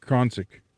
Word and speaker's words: Koncek: K-O-N-C-E-K? – Koncik.